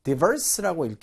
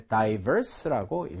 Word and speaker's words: diverse: 자, 이 단어는 일단 발음이 diverse라고 읽기도 하고요. 그 다음에 diverse라고 읽기도 합니다. 자, 그래서 읽는 사람에 따라서 diverse, diverse가 둘 다 가능하니까 자, 요 발음 일단 주의하시기 바라고요.